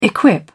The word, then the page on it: equip: British English